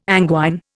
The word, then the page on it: anguine: Indian